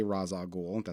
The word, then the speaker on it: Ra's al Ghul: Or Ra's al Ghul, depending on how you want to say it. I always say Ra's al Ghul. That's just the way that I was.